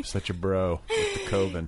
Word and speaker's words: coven: Is it pronounced coven? – Yeah. – I've been saying coven for way too long.